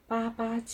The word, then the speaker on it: 八八: 中国的父亲节也叫八八节，这是因为八八与爸爸的发音很像，而且从文字角度看，父字这个字很像是由两个八字组成。